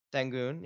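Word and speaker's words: Şengün: I'll I'll I'll pronounce Alperen Shangun. – Shangun, yeah.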